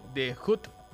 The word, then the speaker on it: The Hut: Y sorpresa, hoy salió un estudio de parte de la gente de, The Hut, así se pronuncia gringo.